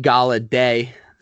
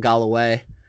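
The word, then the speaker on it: Golladay: And then the receivers, Kenny Galladay, saying that correctly, not Galloway.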